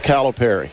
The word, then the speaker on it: Calipari: The official pronunciation, according to the University of Kentucky media guide, it's Calipari. – Okay. – Not Calipari.